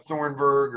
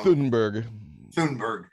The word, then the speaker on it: Thunberg: Greta Thunberg, or I got her name wrong. – Thunberg.